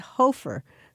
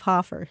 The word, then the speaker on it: Hoffer: He said Hofer. instead of Hoffer.